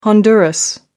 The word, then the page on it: honduras: American English